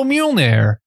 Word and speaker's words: Mjolnir: This was real Mjolnir. The fuck? – That was a really impressive pronunciation there.